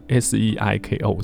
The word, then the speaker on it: Seiko: 专辑同名歌曲《 Canary》是松田圣子第一次自己谱曲，特意在作曲的一栏写了罗马读音 S E I K O Seiko，初看还以为是那个手表牌子精工呢。